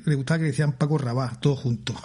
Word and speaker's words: Paco Rabal: Paco Rabal, ¿no? Con acento en la... – Todo seguido. Con la L sin pronunciar. La L líquida.